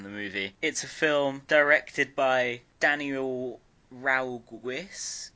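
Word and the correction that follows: Ragussis: Ragusis. Written and directed. – Didn't butcher that at all. – Written and directed by Daniel Ragusis, who doesn't have a Wikipedia page.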